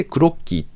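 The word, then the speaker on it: CROQY: 式会社シフトオールはネット接続型電子メモデバイスクロッキーを発表。クロッキーはちょっとだけスペルが違うんですかね、これは。 CRO。アルファベットの QY ですね。これでクロッキーと読むんだそうです。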